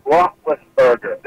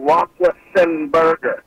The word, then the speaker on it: Roethlisberger: LB, you always pronounce Ben's name wrong. It's Roethlisberger. There is no Roethlisenberger. You're a sports director. Get that name right.